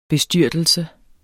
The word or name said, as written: BESTYRTELSES